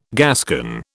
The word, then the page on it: Gascon: British English